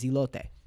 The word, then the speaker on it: elote: I'm not going to go up for sure as hell and say elote because that's not how it's pronounced. The proper pronunciation of the word is elote.